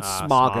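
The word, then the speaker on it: Smough: How do you say that motherfucker's name? I always said Smog in my head. – Yeah, I always say Smog. – I heard people hear Smo. – Ornstein and Smo.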